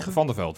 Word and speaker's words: Van der Veld: Kai in het veld, zeg ik het goed? Heet die, dat is die Van der Veld. – Van der Veld, sorry.